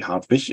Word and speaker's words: Hartwig: I've got all sorts of pronunciations in England and New Zealand of my name. I got used to all of them now, so I respond to all of these different versions. But it's actually Hartwig.